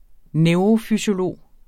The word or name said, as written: NEUROFYSIOLOGER